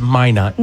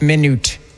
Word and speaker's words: Minot: That it's Minot. – Minot. Minot. Okay.